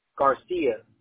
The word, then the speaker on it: Garcia: G-A-R-C-I-A-A-L-V-A-R-E-D. Garcia Alvarez. – Oh, well. Okay. Garcia. Am I saying that right? Garcia? – Garcia.